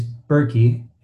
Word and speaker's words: Berkey: And my last name is Berkey. It's spelled like Berekey, so Berekei.